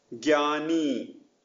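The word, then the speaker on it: Gyani (ज्ञानी): Gyr, like gyr with less emphasis on the R. Gyani, learned person.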